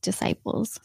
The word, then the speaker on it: disciples: I believe Jesus would wash the feet of his disciples. So, yes. – He would wash the feet of his disciples? – Disciples. – Disciples. I said it wrong. – You did. You put an F in there.